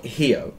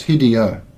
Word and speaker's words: Hideo: But Hideo or Hio? – No, it's Hideo. It's Hideo H-I-D-E-O.